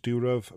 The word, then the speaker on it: Durov: Now, yes, I am aware that I pronounced the surname wrong. I think it's Durov,